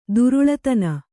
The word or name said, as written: ಧಾರಾಳತನ